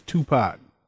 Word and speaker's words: Tupac: His name is Tupac.